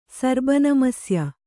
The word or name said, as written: ಸರ್ಬನಮಸ್ಯ